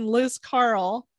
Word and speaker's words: Liscarroll: So if you're ever in Liscarroll, is how you pronounce it, looks like Liscarroll, Ireland, you can go see this village, see the castle, go to the sanctuary.